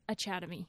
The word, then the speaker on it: Achademy: It's like Chad inside of Academy. – Yeah. – So it's Achademy.